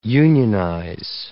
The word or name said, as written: unionize